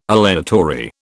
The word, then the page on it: aleatory: British English